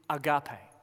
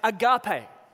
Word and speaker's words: agape: Those scribbles up there, that whatever that is, it means, or it's pronounced agape. Agape. Can you say it with me? Agape.